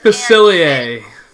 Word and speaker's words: Facilier: No, Dr. Fossilier, whatever it is. And it says... – Facilier.